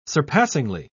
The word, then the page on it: surpassingly: British English